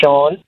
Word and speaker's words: Sean: My name is Sean. – Sean. And you don't spell that S-E-D. What's up, man? – S-E-A-N.